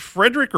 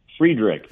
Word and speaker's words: Freiderich: Do you pronounce it Frederick or Friedrich? – Friedrich. – Friedrich. Okay.